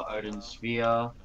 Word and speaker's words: Odin Sphere: Working on a review for Odin Sphere.